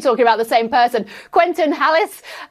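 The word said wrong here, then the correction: Halys: and Quentin Alice. You've absolutely nailed it much better than me.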